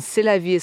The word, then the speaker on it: Rrose Sélavy: The R of rose can be rolled exaggeratedly, and Sélavy is pronounced like C'est la vie. That's life in French.